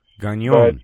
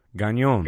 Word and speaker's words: Gagnon: So the last name is Gagnon, and it's pronounced Gagnon if you're French. – Gagnon. – But to everyone else, you can say Gagnon. – Gagnon.